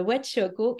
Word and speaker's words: Wetchoko: Donc, euh, je suis très heureuse aujourd'hui d'avoir avec moi le docteur euh, Wetchoko et reprends-moi si je ne, le prononce pas comme il faut, c'est correct? Super.